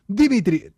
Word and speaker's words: Dmitry: Dmitry, no, se dice exactamente así, se pronuncia así en ruso. Dmitry, tendrías que...